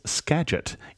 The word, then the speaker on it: Skagit: We said Skagit on the podcast, and the name of that river and county in Washington is Skagit. Even though it's spelled with a G, it's pronounced with a soft G.